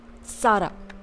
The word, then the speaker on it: tsara: You can have tea lovers can have gurgurcha cha or butter tea or they say it as sara and it is spelled as T S A R A.